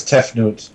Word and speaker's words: Tefnut: We have the avatars for the Egyptian gods Hathor, Osiris, Horus, Isis, and Difnut. – I think it was Tefnut. – Tefnut, it begins with a D, but maybe it's pronounced with a T.